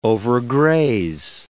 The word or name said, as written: overgraze